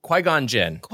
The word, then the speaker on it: Qui-Gon Jinn: Okay, how do we say his name? – Let's talk about, which one? – Qui-Gon Jinn? – Qui-Gon Jinn.